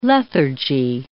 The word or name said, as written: lethargy